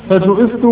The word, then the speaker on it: فجئت: وقال في رواية يونس فجئت بجيم مضمومة ثم همزة مكسورة ثم تاء مثلثة ساكنة ثم تاء الضمير.